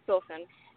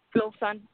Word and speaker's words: Filson: Hi, my name is Filson, Joy Filson, and I'm calling from Columbus, Ohio. – What'd you say your name was? – Filson. Filson. – Filson. – Yeah. Phil like Philip's son, like the sun.